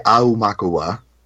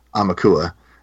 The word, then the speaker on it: Aumakua: I've heard 3 pronunciations. I say Aumakua, 5 syllables, because I think that sounds the most Hawaiian, I guess. I think most people just say Aumakua, most people say Amakua. I've also heard Amakwa, like 3 syllables.